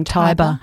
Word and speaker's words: Tiber: We've got Dominic Allen, who has he's written and directed a film called Tiber, as in the river Tiber. Perhaps I'm saying it wrong. – Tiber.